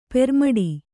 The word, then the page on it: ಪೆರ್ಮಡಿ: permaḍi